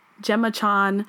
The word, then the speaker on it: Gemma Chan: Jenna Chan. What is it? Gemma Chan.